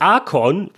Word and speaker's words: Archon: Dieses Archon, ist die einzige richtige Aussprache,